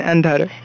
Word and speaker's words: Andhare: Yeah, Vrujen, Andhare.